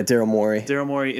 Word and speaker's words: Morey: Yeah, Daryl Morey in the ass.